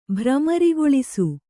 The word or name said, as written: ಭ್ರಮರಿಗೊಳಿಸು